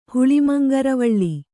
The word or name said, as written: ಹುಳಿಮಂಗರವಳ್ಳಿ